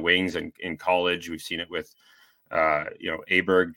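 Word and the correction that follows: Åberg: turning Aubert, I guess is the proper pronunciation, turning pro so quickly and winning.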